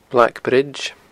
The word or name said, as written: Black Bridge